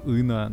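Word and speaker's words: Ng: Слушай, ну их столько, просто типа, условно говоря, онлайн-курс про, ма- про машинное обучение, ну, Эндрю Ина на Курсере. Эндрю Ин. Эндрю Нг, У него на, на английском фамилия состоит из двух букв, и обе согласные. – Н-G. Но читается как ИН.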